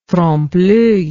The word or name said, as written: trompe l'oeil